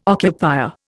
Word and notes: occupier: Indian

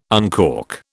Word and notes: uncork: British English

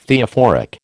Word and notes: Theophoric: American English

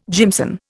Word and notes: jimson: Indian

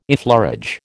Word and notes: effleurage: American English

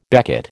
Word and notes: becket: American English

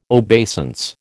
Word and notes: obeisance: American English

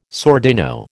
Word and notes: sordino: American English

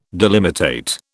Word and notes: delimitate: British English

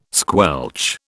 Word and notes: squelch: British English

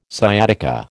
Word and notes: sciatica: American English

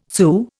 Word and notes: Sioux: Indian

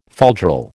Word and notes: falderal: American English

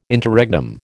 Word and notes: Interregnum: American English

